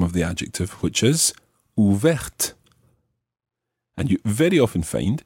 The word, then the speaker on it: ouverte: So, ouvert ends in a T, which is silent. Ouverte ends in the TE, making the T pronounced.